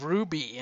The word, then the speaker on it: RWBY: At the bottom, we have Ruby, and it's spelled RWBY.